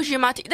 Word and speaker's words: Giamatti: Is how you pronounce it? – Oh, Paul Giamatti.